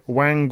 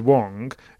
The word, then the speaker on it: Wang: Talking of pronunciations, apparently I've been getting, not only we've been confusing Wang with Wong, which is the correct pronunciation of the player that's been just winning on a regular basis, it also should have been Chang rather than Qiang.